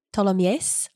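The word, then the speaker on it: Tholomyès: I think that the little accent gauche over the E means that you should pronounce the E, Ptolemies, like that. – Oh, that sounds more French.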